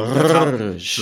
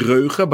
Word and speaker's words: Reuge: So the music box maker is Rouge. It's R. – Is that how you say it? – I think so. Rouge. It's Rouge. French, I guess. Okay. It could be Reuge, but just to spell it quickly for our listeners, it's R-E-U-G-E.